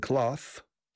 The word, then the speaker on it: cloth: Selling grain, selling meat, selling cloth. Cloth is telas. Con ese se pronuncia clothes.